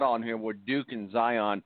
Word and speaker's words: Zion: Zion.